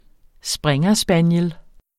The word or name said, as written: springerspaniel